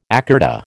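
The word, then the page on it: écarté: American English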